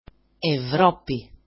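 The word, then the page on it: Ευρώπη: evro-pi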